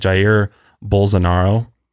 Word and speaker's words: Jair Bolsonaro: It's like Jair Bolsonaro.